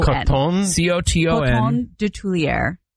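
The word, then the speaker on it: Coton de Tulear: One T. C-O-T-O-N. – Coton de Tulier. – How the hell do you spell that? – Of Tulier. D-E. T-U-L-E-A-R.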